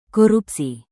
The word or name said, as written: korupsi